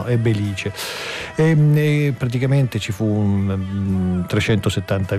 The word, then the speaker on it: Belice: Nella Sicilia occidentale è il terremoto del Belice, attenzione, l'accento è come diciamo noi, Belice.